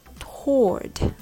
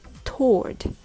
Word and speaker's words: toward: Although it's spelled with W in it, you don't have to pronounce the W. Did you know that? So just say toward, like T O R D, toward. There are some variations of how to pronounce this word. Some pronounce it with W sound, like toward or toward. But most people like to pronounce it Toward.